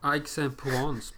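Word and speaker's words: Aix-en-Provence: Aix en Provence, precis.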